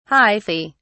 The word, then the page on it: hyphae: British English